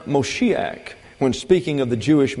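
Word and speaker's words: Moshiach (משיח): Anglicization of the Hebrew word Moshiach. That's M-O-S-H-I-A-C-H, pronounced Moshiach.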